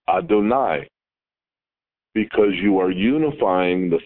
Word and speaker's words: Adonai: And so you can be reading a prayer, and you'll see the word Aleph, Dalet, Nun, Yud, and you'll pronounce it Adonai. And you'll go along, and you'll see Yud, Hey, Vav, Hey, and you'll pronounce it Adonai.